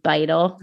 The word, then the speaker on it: Beittel: Beittel. I will spell that. B is in boy, E I T T E L.